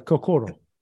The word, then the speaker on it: kokoro: Um, in the book, uh, you highlight a Japanese saying, please forgive my pronunciation, kokoro? – Yeah, it's pretty close, kokoro.